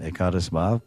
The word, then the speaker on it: Ekadas Bhav: I said some devotees they don't come in this line of Siddha Pranali Diksha and Ekadas Bhav, which is the right way to say it, Ekadas Bhav.